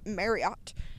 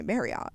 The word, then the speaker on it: Marriott: And I had points from Marriott.